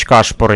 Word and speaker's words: Кашпур: Но ну, это как раз таки тот э, Владимир Терентьевич Кашпор, я правильно говорю? да.